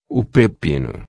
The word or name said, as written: pepino